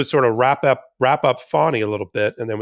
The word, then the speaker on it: Fani: So let's talk about another name that it's taken me a year to pronounce properly, which is Fonny Willis.